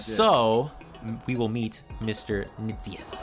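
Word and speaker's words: Nicieza: Fabian Nitziezza, welcome to the show.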